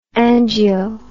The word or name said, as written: angio